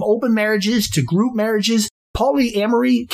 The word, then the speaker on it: polyamory: So it is probably because of that song that some people pronounce the word polyamory as polyamore. In general, though, the typical pronunciation is simply polyamory.